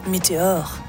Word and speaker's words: Meteor: Udtalt Meteor. Sangen er nemlig på fransk og indspillet i Paris med mit nye fantastiske bekendtskab, produceren Thibaut Frisoni.